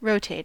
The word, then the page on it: rotate: American English